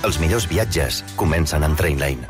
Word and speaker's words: Trainline: Es pronuncia Trainline. Descarrega't l'app de Trainline i estalvia comparant milers de trens i tarifes.